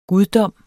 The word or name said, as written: GUDDOMMENS